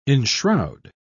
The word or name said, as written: enshroud